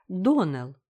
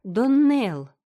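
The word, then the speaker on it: Donnell: «Клариса Эльмира сказала мне, что вы произносите нашу фамилию – Доналл. Так вот, мисс Ширли, правильное произношение нашей фамилии – Доннелл. Ударение на последнем слоге.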